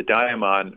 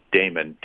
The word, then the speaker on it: daimon: The daimon, or some people would pronounce it daemon, D-A-I-M-O-N, uh, is the true self and he'd consult this true self inside.